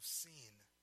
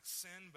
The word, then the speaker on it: Sin: They set out from Elim, and all the congregation of the people of Israel came to the wilderness of Sin. And you read that, it looks like sin, but in Hebrew that would have been pronounced Sin.